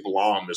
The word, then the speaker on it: Blame: And if you look, I think it's Blom is actually how you're supposed to pronounce it, but I'm going to call it Blame because I was born in Chicago.